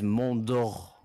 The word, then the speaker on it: Montour: Like, for example, because I'm switching to Montour. You guys have been pronouncing it wrong. It's Montour. You got to go that in your throat.